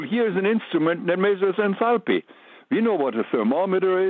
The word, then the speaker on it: enthalpy: I pronounce it, by the way, enthalpy. That's what my teacher used when I took thermodynamics courses years ago.